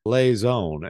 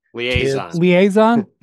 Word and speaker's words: liaison: Liaison is the perfect word. You just didn't know how to say it.